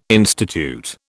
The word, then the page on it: institute: British English